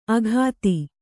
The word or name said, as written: ಅಘಾತಿ